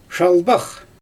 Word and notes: ʃalbax: Xyzyl

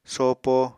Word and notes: sopo: Apatani

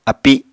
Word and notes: api: Apatani

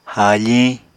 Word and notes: haafiñ: Apatani

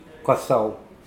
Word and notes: koasoa: Mokilese

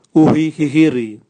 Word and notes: uhi gigiri: Raga

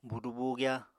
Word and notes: buudu-buugyá: Apatani

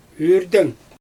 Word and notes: yrdɛɲ: Xyzyl

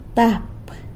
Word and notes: tap: sanlucasquiavini

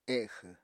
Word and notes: exw: Apatani

